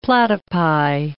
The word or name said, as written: platypi